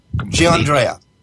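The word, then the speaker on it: Giannandrea: And I apologize, John, for Gianandrea. – For completely ruining the pronunciation, and thank you for the correction there.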